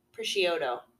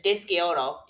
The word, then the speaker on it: prosciutto: He did change it, how to pronounce it. He called it like prosciutto. – Yeah. – I remember I said dischiotto.